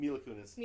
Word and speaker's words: Mila Kunis: Or Mila Kunis, is that how you say her name? – Mila Kunis.